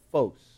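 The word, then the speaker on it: phos (φῶς): In Greek, the word for light is the word phos. That's how you say it in Greek, phos.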